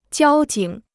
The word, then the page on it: 交警: jiāo jǐng